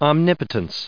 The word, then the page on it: omnipotence: om*nip*o*tence; American English